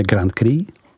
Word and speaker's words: Grand Cru: A konečně vína statutu Grand Cru, vyslovujeme Grand Cru, která se podílí 1,5% na celkové produkci burgundských vín a stojí v jakosti této oblasti nejvýše.